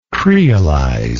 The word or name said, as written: creolization